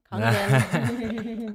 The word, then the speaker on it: Kangen: And I actually had a friend that I sold the Kangen machine to. I said it right. Kangen.